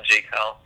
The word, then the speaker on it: Jaquell: So I saw that you made some stuff on Adam Killa's mixtape, and then Jacquel, is that how you say his name? – Yeah, I think it's, he pronounces it different ways. I call him Jacquel.